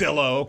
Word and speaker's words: Zillow: It rhymes with pillow, all right? It's Zillow, okay?